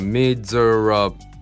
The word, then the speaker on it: miserable: これは Miserable、まあ 4 音節か。でストレスは最初の目、この一番大事な発音のところは、まあ、難しくはない。